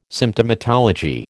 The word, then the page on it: Symptomatology: American English